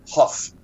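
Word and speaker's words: Hough: Yeah, and oh, by the way, you do know that it's John Ho. Is it John Ho? How do you pronounce it? – I would say Ho or Hoff. Huff.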